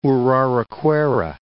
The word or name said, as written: Uraricoera